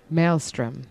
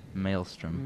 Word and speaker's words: maelstrom: It's got one of those weird AE combos that you might not expect. – How do you pronounce it? – Maelstrom. – Maelstrom.